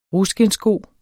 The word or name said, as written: ruskindssko